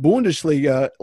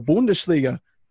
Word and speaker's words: Bundesliga: Amazingly, Stone, you, I don't even think you know what you just did, but you said Bundesliga. Like, I'm pretty darn sure that's how you say it in Deutsch. Like, we say the Bundesliga here in the United States, but I think you had that little H at the end, the Bundesliga.